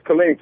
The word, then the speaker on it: Killeen: Am I saying that right? – It's Killeen, Texas, sir. Killeen.